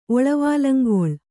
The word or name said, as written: ಒಳವಾಲಂಗೊಳ್